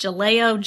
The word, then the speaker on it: Jaleo: There's also a restaurant, I believe it's pronounced Jaleo, J-A-L-E-O, that will be opening sometime in 2018 where Portobello's has been located.